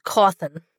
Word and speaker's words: Cawthon: I think it's Cawthon. That's what I always say. – Yeah, I do too. – Yeah, it's Cawthon. I mean